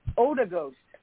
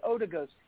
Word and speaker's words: Ouagadougou: Oh, there's going to be a silent letter. Ouagadougou's. Yeah, okay, that's how you pronounce it. Ouagadougou's.